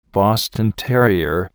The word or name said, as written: Boston terrier